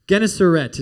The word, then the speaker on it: Gennesaret: Gennesaret is how you pronounce that.